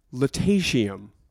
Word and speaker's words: Lutetium: Tough pronunciation on this. Lutetium. – Ooh. – Yeah. Spelled L-U-T-E-T-I-U-M. – Oh, wow. – Lutetium.